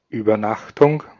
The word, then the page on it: night: American English